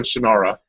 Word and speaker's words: Shannara: No, I guess we called it the Sword of Shannara, and then we learned from Terry Brooks that he called it Shannara. So Shannara is the official title of the game.